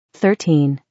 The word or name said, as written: 13